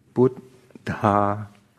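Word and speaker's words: buddha: So there are actually two syllables in there. In other words, the dha is written kind of below the put. So it's like namo put dha.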